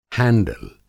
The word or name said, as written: हैंडिल